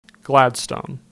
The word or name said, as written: Gladstone